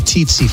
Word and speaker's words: tsetse: No, it's a teetsy fly. You ever it's not spelled that way. – It's spelled T-S-E-T-S-E. – That doesn't look like teetsy to me. It looks like tsetse in the T.